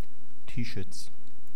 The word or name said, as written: Tieschitz